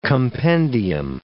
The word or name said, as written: compendium